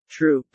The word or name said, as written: troop